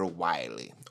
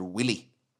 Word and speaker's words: Wily: And when it's all done, you attack Dr. Wily. Or, how I used to call him when I was a kid, Dr. Willy.